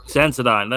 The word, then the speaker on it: Sensodyne: Cenodrome? Is that how you pronounce it? Cenodrome? However, do you know what Sensodyne? I mean by that? Sensodyne. There you go.